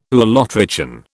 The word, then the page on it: ulotrichan: British English